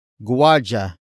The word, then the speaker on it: guwardiya: Jaguar is spelled as J-A-G-U-A-R. It refers to a security guard. Jaguar comes from the Tagalog word guardia. Guardia. Guardia, which is spelled as G-U-W. A. R. D. I. Y. A.